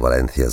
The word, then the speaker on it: Valencia: Ich sage Valencia, wie ein Castellano Valencia sagen würde. C ausgesprochen wie das TH im Englischen.